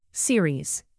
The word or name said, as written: series